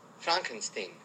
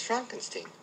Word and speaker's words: Frankenstein: Frankenstein. You're putting me on. – No, it's pronounced Frankenstein. – Do you also say Frederick? – No, Frederick. – Well, why isn't it Frederick Frankenstein? – It isn't, it's Frederick Frankenstein.